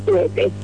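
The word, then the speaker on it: Tehte: La calle Magno Tuete, se escribe T-E-H-T-E y se pronuncia Tuete.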